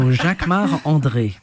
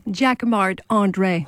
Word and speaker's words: Jacquemart-André: Jacquemart-André. You say it like a Frenchman. – Of course, because I'm French. So Jacquemart-André. – And say it so we can understand it. – Jacquemart-André. – Oh, goodness.